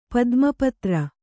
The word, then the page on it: ಪದ್ಮಪತ್ರ: kannada